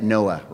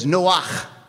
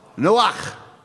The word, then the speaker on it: Noach: Now, I'm going to do his pronunciation once. You're going to do it with me a second time, and then we're going to keep it at Noah, right? But it was Noach. Noach.